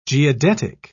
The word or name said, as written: geodetic